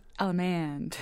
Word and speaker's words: allemande: It's usually pronounced allemande, right? With a little twang. I'm from North Carolina. Allemande.